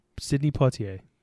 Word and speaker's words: Sidney Poitier: you said his name different every time. – I don't know how to say it. – Sidney Poitier. – Okay, what he said.